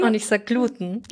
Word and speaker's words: Gluten: Das ist, im Duden steht, soweit ich weiß, Gluten, aus dem Grund, dass sehr viele wissenschaftliche Begriffe auf diese betonte letzte Silbe enden.